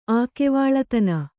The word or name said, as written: ಆಕೆವಾಳತನ